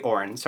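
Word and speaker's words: Auryn: and the Orion, the childlike Empress's necklace of interwoven snakes to protect him. – Or the Orin, I mispronounced it.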